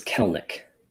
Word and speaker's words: Kelenic: Jared Kelenic, is that how you pronounce that? Kelenic? – I believe it's Kelenic.